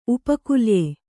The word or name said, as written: ಉಪಕುಲ್ಯೆ